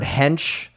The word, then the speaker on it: hench: He did reach out and just say, hey, just few points for the future, very kindly telling us that when he says the word hench, it's pronounced like bench and is slang for built or muscular.